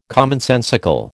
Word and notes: commonsensical: American English

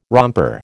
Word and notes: romper: American English

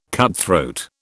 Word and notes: cutthroat: British English

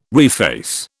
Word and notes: reface: British English

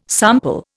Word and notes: sample: Indian

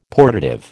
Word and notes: portative: American English

